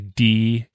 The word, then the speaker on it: D-EVO: And anybody that's wondering how to spell that, it's actually D-Evo, but they call it the Devo.